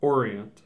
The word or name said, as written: Orient